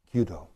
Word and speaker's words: Kyudo: Kyudo.